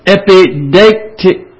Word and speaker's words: epideictic: And the third one is epieictic. Is the best way I can pronounce it. EI in the Greek is a diphthong that sounds like A. So I'm saying it's epideictic. A-C is hard to say. Epideictic. Well, enough of that. It's E-P-I-D-E-I-C-T-I-C.